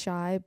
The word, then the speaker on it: 5HY: It's a new metal artist, but the name is pronounced Shy, but it's written 5-H-Y.